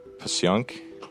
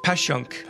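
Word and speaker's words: Passyunk: This word is spelled P-A-S-S-Y-U-N-K. P-A-S-S-Y-U-N-K. – Pashyunk. – Excellent. It's Pashyunk.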